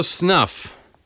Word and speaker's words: snuif: So snuff is a Dutch name for ground powdered tobacco. – Wow. – They spell it S-N-U-I-F, but it's pronounced snuff as far as I know.